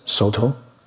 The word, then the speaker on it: Soto: One is Cao Dong, Japanese pronounce it Soto.